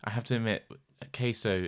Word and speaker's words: Caeso: C-A-E-S-O. – C-A-E-S... Queso, yeah. – Queso? – Yeah. – Can you say so? But it wouldn't be, would it? – It's a hard C.